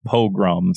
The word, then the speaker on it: pogrom: Dilbert in the chat says it's pronounced pogrom.